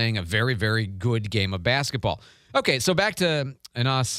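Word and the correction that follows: Enes: Kanter, or Anas, I always want to say it wrong. I think it's Ines is how you say it.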